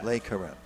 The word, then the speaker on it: Le Careme: Lee Careme. – Lee Careme. – Because most of cigar brands is La something. – I think in the French pronunciation, this would be Le. – Yeah. – Le Careme.